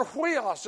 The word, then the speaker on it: huios: And it's the word huios. It's spelled U-I-O-S with an H sound in front.